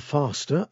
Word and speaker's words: Fásta: Now, this is a band that's new to me called Faster. I think that's how you pronounce it. It's F-A Fada S-T-A, an Irish name.